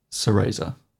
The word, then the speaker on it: Sereza: Sereza. – It's Sereza. That's how it's pronounced in the game. Ugh. She's not Spanish.